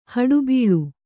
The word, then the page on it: ಹೊಡಬಾಳು: hoḍabāḷu